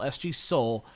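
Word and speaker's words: Soal: His name is spelled like Cole, but it sounds like Soul. S.G. Soul.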